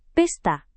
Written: pesta

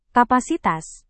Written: kapasitas

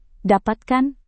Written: dapatkan

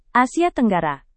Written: Asia Tenggara

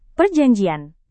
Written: perjanjian

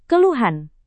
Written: keluhan

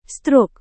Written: stroke

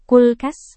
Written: kulkas

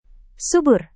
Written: subur

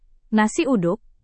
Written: nasi uduk

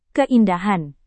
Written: keindahan

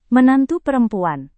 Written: menantu perempuan

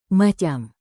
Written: macam

